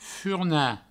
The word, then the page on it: furnar: furna